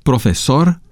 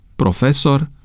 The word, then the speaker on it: profesor: De asemenea, există în limba română unele neologisme mai vechi, cu două variante de pronunțare, ambele acceptate de normă. Așa sunt cuvintele antic, profesor, trafic. Așa se pronunță aceste cuvinte după etimonul francez, dar norma a acceptat și pronunția antic, profesor, trafic, după obișnuința românului de a accentua cuvintele pe penultima silabă.